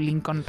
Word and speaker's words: Lincoln: Perquè jo normalment dic Lincoln. Faig una mica l'esforç aquell. Sé que és més fàcil dir Lincoln, en català o castellà. – L-N, eh? – Però jo procuro fer la L-N, Lincoln.